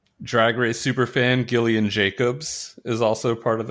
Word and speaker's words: Gillian: Gillian with a hard G. Hard G.